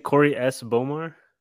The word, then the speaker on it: Corey S. Bomar: Is it Corey S. Bomar? – Yeah.